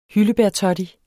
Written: hyldebærtoddy

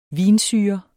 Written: vinsyre

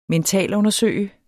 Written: mentalundersøge

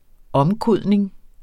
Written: omkodning

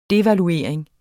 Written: DEVALUERINGEN

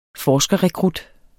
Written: forskerrekrut

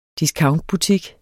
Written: discountbutik

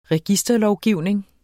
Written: registerlovgivning

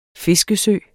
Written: fiskesø

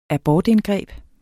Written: abortindgreb

